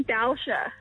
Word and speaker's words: Dalsha: We're great. Are we saying your name right? Delicia. – It's Dalsha. – Dalsha. Because I was just like, why would parents call their kids delicious?